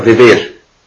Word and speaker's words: vivir: Que en España, es interesante, se puede escribir vivir con B de burro y pronunciar vivir. ¿Tengo razón o me estoy equivocando? No sé cómo se dice en España. ¿Vivir o vivir? – Vivir. Vivir. Con B. Como si fuera con B.